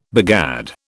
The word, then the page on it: begad: British English